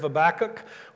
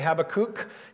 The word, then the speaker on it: Habakkuk: He was a contemporary of Habakkuk. We're going to study Habakkuk, or if you say Habakkuk, you know, there's two ways of pronouncing that. I've always heard Habakkuk, but if we're going to study Habakkuk next week, he was a contemporary of Habakkuk.